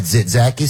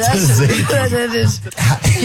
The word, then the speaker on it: Tzatziki: Tzatziki.